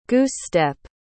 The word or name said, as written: goose-step